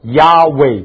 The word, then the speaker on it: Yahweh: 那个字希伯来文只有一个字，那个字如果犹太人他们试着发音，事实上他们不敢发音，因为那就是神的名字，发得出音叫做 Yahweh God，就是刚刚我们唱的诗歌说耶和华是爱。耶和华的希伯来文的原文就是 Yahweh God， Yahweh 的意思就是 I am who I am，他不是 I was，他不是 I will be，他是一个永远的现在式。